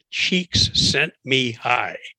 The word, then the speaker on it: Csikszentmihalyi: There's a fellow named, he's got a difficult name, Csikszentmihalyi. – Oh my, that is difficult. – He says to pronounce it Csikszentmihalyi.